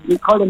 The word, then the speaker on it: Nicole: Wie spricht man deinen Namen richtig aus? Schreiben tut man ihn N-I-K-O-L-E, stimmt es? – Nicole mit C. Eigentlich heiße ich ja Nicoline, aber die meisten nennen mich Nicole.